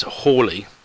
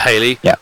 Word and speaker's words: Halley: it is written as Hawley, even though it's spelled as Halley. And it's not Halley, who was actually just a pop star.